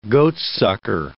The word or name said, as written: goatsucker